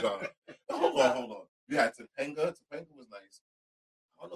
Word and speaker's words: Topanga: Topanga.